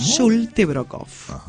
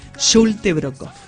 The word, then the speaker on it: Schultebrockhoff: Difícil de escribirlo, te digo, pero se pronuncia así. No se escribe con Y ni con L, sino con S, H, U, dos L's. Y así sigue, te digo, es re difícil escribirlo. Termina hasta Olfe de apellido, pero se pronuncia así, Schultebrockhoff.